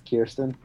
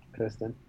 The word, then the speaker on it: Kirsten: It's Kirsten, so I feel like that's Kirsten, not Kristen. – Have I been saying it wrong this whole time? – I feel like if it was Kristen, it would be K-R-I, not K-I-R.